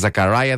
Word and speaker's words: Zakaria: Έτσι προφέρεται τελικά. Είναι Zakaria, δεν έχει σχέση με Ελλάδα.